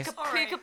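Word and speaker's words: Pukka: Popular opinion, I like puka. – Is that how you pronounce it? Is it puka? – What the hell? – To clarify, you mean puka teas. – Puka tea. – It's puka.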